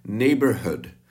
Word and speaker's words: neighborhood: Neighbor, neříkáme hood, ale je to kratší. Jo, jenom Češi vyslovují hood dlouze. Jo, Robin Hood. My říkáme neighborhood. Nebo Robin Hood. Je to takový, no prostě není to tak dlouhý.